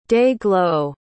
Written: day-glo

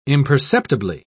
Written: imperceptibly

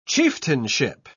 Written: chieftainship

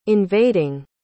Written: invading

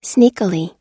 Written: sneakily

